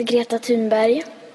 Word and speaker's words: Greta Thunberg: So I ask you, how does Greta pronounce her own name?" – "My name is Greta Thunberg. I am 15 years old. My name is Greta Thunberg." – Okay. That's how she pronounces her name.